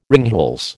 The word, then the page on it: ringhals: American English